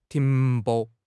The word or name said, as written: 停泊